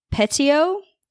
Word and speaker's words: Petiot: It is about Marcel, and I hope I'm saying this right, Petiot. That is how Google said to say it in French.